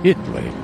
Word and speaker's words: Italy: She drew the butterflies that floated, quiet like her, around Italy, Texas. Spelled just like the country, but they pronounce it Italy.